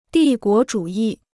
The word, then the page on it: 帝国主义: dì guó zhǔ yì